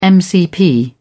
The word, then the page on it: mcp: British English